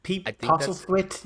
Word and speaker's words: Postlethwaite: Pete Postlethwaite, yeah.